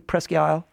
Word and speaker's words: Presque Isle: First, Steve, did I say that correctly, Presque Isle? – Yes, but it's Maine.